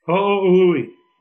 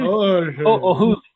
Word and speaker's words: Ho'ohuli: Ho'ohuli. Wyndon Ho'ohuli.